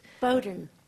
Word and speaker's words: Bowdoin: Bowdoin. – Okay. – That's why it's so difficult. – It is, isn't it? Because it's got a silent W and a silent I in it.